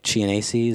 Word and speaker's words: Chianese: Dominic Chianese, is that the guy that nailed it? – Uncle June.